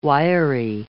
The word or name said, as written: wiry